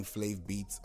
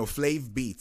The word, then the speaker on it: Flaev Beatz: No, Flaev Beatz.